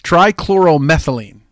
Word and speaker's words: trichloromethylene: Tricryomethylene. Methylene. Tricryomethylene. Trichylomethylene. That's it. Trichylomethylene. That's, wow, really? Is that what, wow, what the fuck? That's crazy. So I really want to say it correctly. It's trichloromethylene.